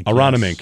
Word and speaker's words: Aronimink: Aronimink. I've been practicing how to say it. The PGA Championship. – Oh, Aronimink. – Aronimink.